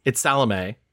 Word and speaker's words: Salome: by the way, I'm not correcting you. It's just you're pronouncing it the way they pronounce in the movie. It's Salome. – Salome. – That's how you say it.